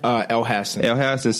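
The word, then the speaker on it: Elhassan: I seen Amin Hassan, how you pronounce his last name? – El Hassan say the reason the Lakers putting up with it is because Lonzo is worth it.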